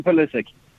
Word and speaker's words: Pulisic: 100% Christian Pulisic.